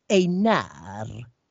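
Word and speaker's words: Einár: med en accent på A-et. Och tänkte, bara för att det ska se märkvärdigt ut. Nej, utan det skulle då uttalas på blatte svenska, Einar. Sån betoning har vi ju inte i svenska. Här heter folk Kalle och Pelle, alltså vi betonar på första stavelsen.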